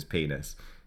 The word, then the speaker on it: PNAS: Yeah, it's like, well, how else are you going to pronounce that? Of course it's PNAS.